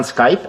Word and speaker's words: Skype: Po angielsku poprawna wymowa tej nazwy to Skype.